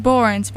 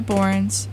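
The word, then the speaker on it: Børns: I think it's just Borns, but it has the slash through the O. So Electric Love by Borns.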